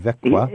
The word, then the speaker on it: Växjö: And also, they've done a lot to help Americans reconnect in Växjö. – Växjö. – Yeah. Mm. It's spelled V-A-X-J-O, Växjö. – A town which I have heard pronounced at least six different ways while I was in Sweden.